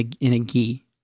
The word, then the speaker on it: gi: In a gi. Is that how you say it? – Yes. – You know, you were in karate. – That's true. I was, absolutely. You're right. And thank you for saying that the right way as well.